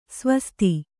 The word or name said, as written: ಸ್ವಾಸ್ತಿ